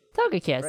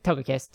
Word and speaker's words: Togekiss: Togekiss. – Right. Isn't it three syllables? – Yeah, you're right. Togekiss.